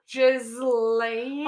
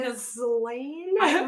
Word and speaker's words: Ghislaine: Ghislaine. – Oh, yeah. – Ghislaine.